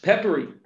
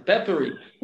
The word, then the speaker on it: Pepperi: Actually, the name of the company should be easy to pronounce. It's just Pepperi. – Okay, yep. – Right at the end, Pepperi.